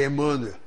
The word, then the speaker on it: Emanuel: Ele disse, eu nunca falei Emanuel, porque é um nome hebraico. Esses nomes hebraicos, todos que terminam em El, têm o acento na última sílaba.